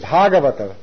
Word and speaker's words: Bhagavata: One name for devotee is Bhagavata. Bhagavan, and that which is in relationship to Bhagavan is called Bhagavata with a long A.